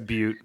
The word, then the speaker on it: Butte: Like, yeah, okay, so the Black Butte that I'm having, or is it Butte or Butte? It's Butte, right? – It's Butte. We're in America. We pronounce it Butte.